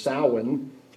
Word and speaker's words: Samhain: It's spelled Samhain, Samhain, but it's Celtic. The way the pronunciation of it is Samhain, is probably the source of the present day Halloween festival.